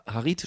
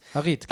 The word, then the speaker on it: Harit: Harit spricht man ihn, glaube ich, aus. – Harit, genau.